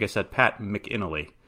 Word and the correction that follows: McAnally: I pronounced it wrong,